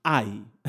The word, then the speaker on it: Hai: Ai era otra ciudad, ¿está bien? Y ellos subieron y reconocieron a Ai. Suena raro porque se escribe H-A-I latina. Reconocieron a Ai.